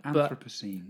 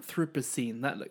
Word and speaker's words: Anthropocene: As in, like, anthropomorphize. – I'm not sure. I don't know if I'm pronouncing it right. – Anthropocene. – But Anthropocene. That sounds much better.